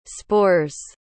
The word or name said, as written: spores